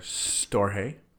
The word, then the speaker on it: storge: They are storge, silent G there.